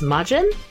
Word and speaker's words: Majin: Also, some characters are named, I don't know how to pronounce this, Majin? M-A-J-I-N? – Yeah.